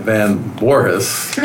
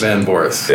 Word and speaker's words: Van Voorhis: Anyways, back to Matt Van Voorhis. – Yep. – Did I say that right? – Yeah. – Say it again for us, just for everyone else. – Van Voorhis. – Van Voorhis.